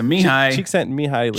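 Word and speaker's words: Mihaly Csikszentmihalyi: I'm pretty sure it's Cheek Cheeksent Mihaly, right? So Mihai Cheeksent Mihaly.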